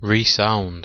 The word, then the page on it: resound: British English